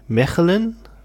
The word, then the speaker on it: Mechelen: Der wohnt nämlich in Mechelen. Wie spricht man das aus? Der Souffleur ist eingeschlafen. – Genau so. Ja, so wahrscheinlich. – Hört sich gut an.